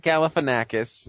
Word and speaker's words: Galifianakis: Zach Galifianakis, which I feel that's how he pronounces his name. – Yeah, that's right.